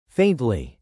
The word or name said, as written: faintly